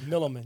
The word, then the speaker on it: Milliman: That's right, Milliman.